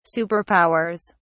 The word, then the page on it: superpowers: British English